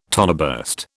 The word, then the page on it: toneburst: British English